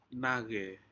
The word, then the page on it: nace: Aneityum